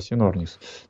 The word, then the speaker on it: Синорнис: Синорнис, я бы это так прочитал бы. Вот, Синорнис, Палеоцен. – Да, но Синорнис это произносится, да? – Да, Синорнис.